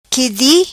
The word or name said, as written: qui di